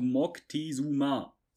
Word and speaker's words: Moctezuma: Montezuma ist eigentlich nur so eine Variante, die sich irgendwann über die Zeit gebildet hat, weil sie halt leicht auszusprechen waren, weil wir dämlichen Deutschen, Spanier und alle können Moctezuma dem Zweiten halt nicht so gut aussprechen. Wirklich so Moctezuma 2. Auch der zweite, der bekannte Montezuma ist nicht der erste, nein, das ist der zweite.